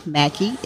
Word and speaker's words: Mackey: My name, again, is Valerie, V-A-L-A-R-I-E. Valerie is spelled different in so many ways, but V-A-L-A-R-I-E, Mackey, M-A-C-K-E-Y.